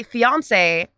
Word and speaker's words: fiancé: No, see, fiance has to rhyme with Beyonce, and that's phonetically how it's said, right?